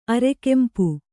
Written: ಅರೆಕೆಂಪು

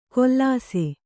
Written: ಕೊಲ್ಲಾಸೆ

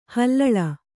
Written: ಹುಲ್ಲಾಳೆ

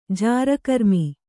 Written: ಜಾರಕರ್ಮಿ

